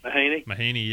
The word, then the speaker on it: Mahaney: And the CJ Mahoney. – Mahaney, yeah.